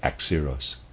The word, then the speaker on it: Axieros: Axeros is spelled A-X-I-E-R-O-S, and I'm pronouncing that Axeros.